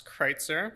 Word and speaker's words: Kreutzer: It is a fun German name. It's Kreutzer.